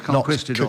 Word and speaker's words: conquistador: I think it's conquistador. – It is conquistador. – Not conquistador. – I'm just a bit common. – No, right. I think that's deviation from the correct word or pronunciation. – Conquistador. – Conquistador. – Oh, when you pronounce it like that. – Marcus, benefit of the doubt. Subject because that is the correct pronunciation.